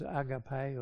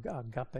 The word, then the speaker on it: agape: There's a higher love, which is agape or agape, as some people pronounce it.